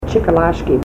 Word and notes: chikalashki: Ninilchik Russian

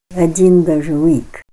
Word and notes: Ad'en dazhaw'ik: Ninilchik Russian

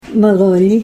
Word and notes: maloli: Ninilchik Russian

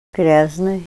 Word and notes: gryaznay: Ninilchik Russian

